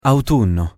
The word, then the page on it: autunno: Italian